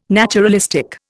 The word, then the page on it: naturalistic: Indian